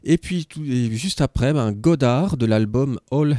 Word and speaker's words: Godard: Jean-Luc Godard, voilà. Je savais que c'était en deux syllabes, mais ça m'avait échappé.